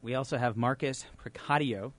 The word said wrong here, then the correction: Preciado: Am I pronouncing that right? – Not even close. – Excellent. Say it again. – Preciado. – Preciado, thank you.